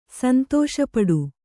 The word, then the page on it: ಸಂತಸಪಡು: Kannada